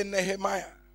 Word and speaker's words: Nehemiah: Say Nehemiah. You may pronounce it differently, but this is my way of pronouncing it. Nehemiah. Or oh, Nehemiah. That one is also good. But I prefer Nehemiah.